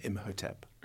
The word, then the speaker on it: Imhotep: How are you saying Imhotep? – Imhotep, So we pronounce the H.